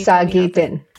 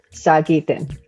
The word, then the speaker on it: Sagitin: Can you tell me how to say it? And it's Cree, right? That's the, can you? – Sagitin. – Sagitin. – Sagitin. Yes, I love you.